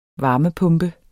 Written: varmepumpe

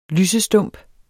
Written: lysestump

